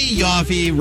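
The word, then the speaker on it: Yoffe: My favorite columnist, bar none, Emily Yaffe, rhymes with coffee, tastes like toffee.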